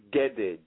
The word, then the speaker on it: gede: You said gede or gebe? – Gede, G-E-D-E, gede.